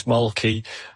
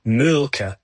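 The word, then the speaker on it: Mölkky: We use Mulkey. Most of the people we play against use Mulkey, but the Finns have a very unusual pronunciation, which, please forgive me, I'll try, it's Mulka.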